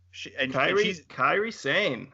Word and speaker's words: Kairi Sane: And Doku is also known as, forgive me if I pronounce these incorrectly, but Kairi Sane. – Kairi Sane. That's Kairi right.